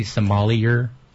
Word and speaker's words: sommelier: All right, so for you, the listener who does not know, a sommelier, which is am I pronouncing that correctly? Sommelier? – No, that's how it looks, but it's sommelier.